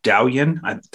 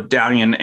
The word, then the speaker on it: Douyin: and the Chinese app was called Douyin. Did I say that right? I'm not sure. – You did. Yeah. – So Douyin, and the Chinese company is called ByteDance.